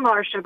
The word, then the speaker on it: Marcia: So in down south, you are Marcia, unless your name is spelled M-A-R-S-H-A. – Or Marcia. Okay, but you're Marcia, correct? – Yeah, I'm Marcia, but C-I-A.